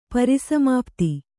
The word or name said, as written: ಪರಿಸಮಾಪ್ತಿ